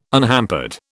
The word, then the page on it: unhampered: British English